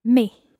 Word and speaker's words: mes: M-E-S. – Now, when you said the word for my in the plural form, mes, you didn't pronounce the S. But then when you said mes enfants, you pronounced the S.